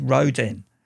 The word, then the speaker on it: Roden: Oh, and whilst we've been on air, the Hit Priests have been in contact and told me that I pronounced their new album wrong. I mean, me. Pronounced something incorrect? Surely not. Apparently, it's Rodin.